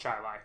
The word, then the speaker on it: Chile: You know, there's a town near, there's a city near Rochester that's called Chile. Spelled Chile. It's pronounced Chile.